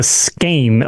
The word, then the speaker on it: skein: It's a skein. It's like a skein, of yarn, apparently. – Right. Yeah. So, like, skein beetles and scorpions and spiders, S-K-E-I-N.